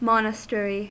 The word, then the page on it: monastery: American English